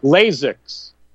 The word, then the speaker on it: Lasix: It almost sounds like the laser eye surgery or something. You know, Lasix is the way they pronounced it.